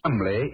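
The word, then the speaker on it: Cholmondeley: Being an American, he had no reason to know that the name Chumley is in no way pronounced the way it is spelt. – Oh, I never thought of that. Chumley. That name's spelt C-H-O-L, Chol, M-O-N, Mon, D-E-D-E-R, L-E-Y.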